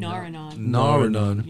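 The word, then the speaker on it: Nar-Anon: we mentioned Nalanon. I keep saying it wrong. – Naranon.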